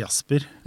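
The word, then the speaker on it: Jazzper: Jas jeg, jeg, sier Jasper.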